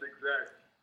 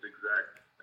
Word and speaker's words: Exacte: Yeah, it's Exacte. A lot of people say Exacte. You're not the only one, but it's Exacte, actually.